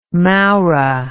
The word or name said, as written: mowrah